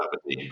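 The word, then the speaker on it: abrdn: It used to be called Aberdeen, but it's pronounced Aberdeen.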